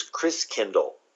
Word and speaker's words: Christkindl: And the German pronunciation of Christ child is Christkindl. Like kindergarten, kindercare, kind means a child, and Christ means Christ.